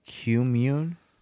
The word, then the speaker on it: Keemun: Traditionally, Earl Grey was made from black teas such as China Kumyun. It's spelled K-E-E-M-U-N. Kumyun, that's how I'm pronouncing it.